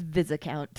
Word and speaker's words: Viscount: It's Viscount. There's an S. – I'm sorry, but we're in England and we pronounce all the letters.